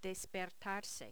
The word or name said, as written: despertarse